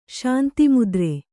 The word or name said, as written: ಶಾಂತಿಮುದ್ರೆ